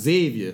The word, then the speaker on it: Xavier: And I feel like with two Xavier grads in the room, I kind of feel like it. Kendra sitting right over here, Startup Nation. And she tells me it's pronounced Xavier when you're down there.